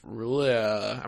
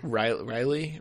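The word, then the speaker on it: R'lyeh: I'm pretty sure it's pronounced Riley. – Riley? Mists of Riley. – Pretty certain. – Like Mists of Rilo Kylie.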